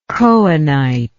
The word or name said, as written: cohenite